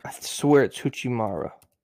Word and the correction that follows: Hachimura: Yeah, Hachimura.